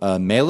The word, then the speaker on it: Mele: They have a brand of ukuleles there called Mele. That's how it's pronounced, I think. M-E-L-E.